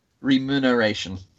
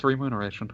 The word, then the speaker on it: remuneration: remuneration. – Uh, oh yeah, it's remuneration. Huh.